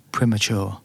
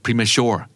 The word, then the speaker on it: premature: premature premature ออกเป็น prem เลยนะครับ stress on the first syllable right premature